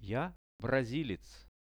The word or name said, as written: ja brazilec